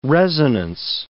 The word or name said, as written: resonance